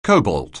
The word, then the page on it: cobalt: anglais